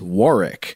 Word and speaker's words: Warwick: It was recently mentioned on one of your podcasts that the correct pronunciation for what is spelled Warwick, as in Warwick Davis, is Warwick.